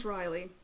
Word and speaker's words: wryly: She even says, she like slides to the floor and chuckles wryly. Did I say that right? – Yeah. – Okay.